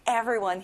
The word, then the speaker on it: everyone: 모든 사람, 모두, everyone. 여기도 역시 앞에 강세가 들어가네요.